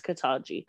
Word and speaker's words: Ketanji: Is it Ketanji? – Mm-hmm. – I think it's Ketanji.